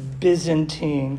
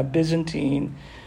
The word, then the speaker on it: Byzantine: And that's pronounced Byzantine because there's no business like show business. You'll never forget it now. Byzantine.